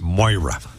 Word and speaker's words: Maire: It's spelled Mayor, M-A-I-R-E, but apparently it's pronounced Moira.